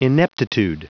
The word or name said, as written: ineptitude